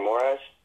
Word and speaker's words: Moraes: I think Marlon Morales, how do you say his name? Moraes. You have to say it.